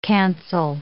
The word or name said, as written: cancel